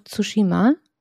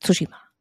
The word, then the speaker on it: Tsushima: Oh ja, wir haben Ghost of Tsushima. Das habe ich schon wieder falsch ausgesprochen. Das heißt Tsushima.